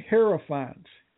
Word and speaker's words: hierophants: but define hierophants. Am I even pronouncing it right? – You are.